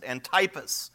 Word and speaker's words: Antipas: His name was Antipas, I think would probably be the right way to pronounce it, Antipas.